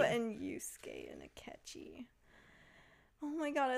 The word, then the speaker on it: Yusuke: Haru and Yusuke? – Oh my god. – Did I pronounce any of those correct? – Yes, that was perfect.